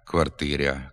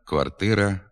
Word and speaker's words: квартира: У частині лівобережних надніпрянських та слобожанських говорів пом'якшується звук «р» перед «а» в окремих словах. Гряд, квартиря, коморя, макітря, ряма. Така вимова також є діалектною, ненормативною. Слід вимовляти «град», «квартира», «комора», «макітра», «рама».